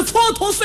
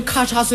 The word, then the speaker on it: 蹉跎: 就是前些日子我看那个电视连续剧、那个蹉跎岁月的题目我念错了。 – 哦，蹉跎俩字不认识？ – 我不认识。 – 你怎么念的？ – 我念成咔嚓岁月。